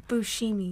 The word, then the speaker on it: Buscemi: Buscemi. – Buscemi or something like that.